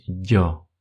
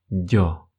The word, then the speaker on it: yo: Например, «я» на испанском языке будет «дё». Прошу вас уходить от произношения «ё», как если бы это была и краткая. Это будет не совсем верно. Попытайтесь произносить это правильно, говорить «дё», «дё».